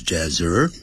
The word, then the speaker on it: desert: desert And some of it is even tropical jungle 他说呢这个国家这边指的是国家大部分地区呢 is desert 哎，这可不要念成 desert如果念成 dessert 的话是点心甜点 D-E-S-S-E-R-T 这个念成 desert 也就是沙漠 And some of it is even tropical jungle 有一些的地方呢甚至是呢就是热带丛林靠近的澳大利亚北方的地方那么就是就是几乎是热带丛林了 Therefore,Most of Australia's citizens prefer living in big cities near the ocean.